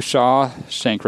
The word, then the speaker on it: Shawshank: It's one word. Shawshank.